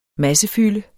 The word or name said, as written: massefylde